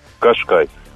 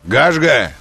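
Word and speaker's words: Qashqai: Кашкай. – Га. Но давайте правильно назовем его, как написано. Гашга, он так произносится.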